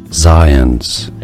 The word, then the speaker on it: Zients: Jeff Zients. – There you go. Just like you said, Jeff Zients.